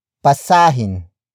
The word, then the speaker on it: basahin: If the root word ends with a vowel, except for O, and that vowel does not have a glottal stop, the letter H is added before the in suffix, making it hin, hin, hin. For example, basahin basahin, basahin.